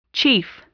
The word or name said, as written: chief